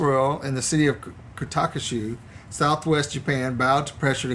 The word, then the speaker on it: Kitakyushu: Kitakyushu.